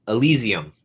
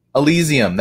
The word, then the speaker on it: Elysium: Is it Elysium or something? How do you pronounce it? – Elysium. – Elysium, that yeah.